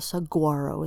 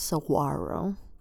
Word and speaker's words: saguaro: I'm from Tucson, Arizona, where saguaro cactus, that's how you say it. It's not saguaro, it's saguaro, right? We don't say the G, the hard G, saguaro.